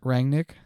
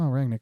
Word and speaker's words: Rangnick: Rangnick? Yeah, you just said it right. Good job. – Oh, Rangnick.